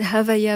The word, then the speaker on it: Havaya: Oui, c'est Havaya avec un H, Havaya, on le prononce très très doucement.